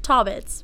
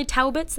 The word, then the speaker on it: Taubitz: Taubitz. – Taubitz, yeah, right. – Well, actually, it was originally Taubitz. That's the German pronunciation.